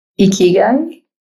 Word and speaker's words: Ikigai: Well, is Ikigai? Is that how it's pronounced? – Ikigai.